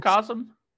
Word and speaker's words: Macrocosm: It's called Macronism. I can never pronounce that word correctly. – Macrocosm? – Macrocosm, that's it.